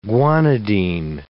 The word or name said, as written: guanidine